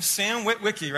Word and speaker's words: Witwicky: I think that's how you say his name. Sam Witwicky, right? – Yeah.